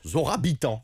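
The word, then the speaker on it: Zohra Bitan: En fait, ça se prononce Zora Bitan.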